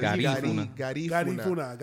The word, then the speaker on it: Garifuna: Put the accent, the emphasis on the you're putting it on the funa. Yeah. – Garifuna.